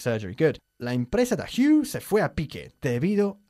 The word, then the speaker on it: Hugh: Ojo, no se dice huge. Siempre me hace reír cuando hablan del actor inglés Hugh Grant y dicen aquí Huge Grant, que literalmente sería Grant enorme, que me hace gracia.